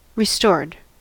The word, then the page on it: restored: American English